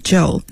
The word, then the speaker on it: Zhou: I mean, a lot of people, like my last name, Zhou, Z-H-O-U, and nobody can pronounce it right. – You get Zhao, right? – Zhao, Zhao, and, you know, right. whatever, so many different variety of sound, but not Zhou.